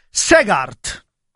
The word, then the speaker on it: Segaert: Comunque Tarozzi, quello che ha vinto oggi si chiama Alex Segaert, è un belga fiammingo, quindi si dice si scrive Segaert e si pronuncia Segaert.